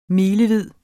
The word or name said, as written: milevid